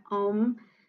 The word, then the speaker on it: Om: It is Hindu in origin and is pronounced, the A is sounded like the A in car. Hindus believe that Om is the sound vibration that underlies the creation of the universe.